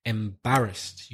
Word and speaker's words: embarrassed: Your pronunciation, you put stress on the first, I think you said like embarrassed or something like that. When you have a long word like that, it's very important to get the stress in the right place. It's the second syllable, embarrassed.